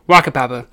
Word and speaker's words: whakapapa: W-H-A. K, A, P, A, P, A. – Waka Papa. – Now you may leave in shame. – Farewell, all. That's just the North American pronunciation. That's not how I pronounce it.